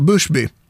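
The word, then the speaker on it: Bushby: Det uttalar han så, Buschby, som folk talar om jättemycket i media just nu.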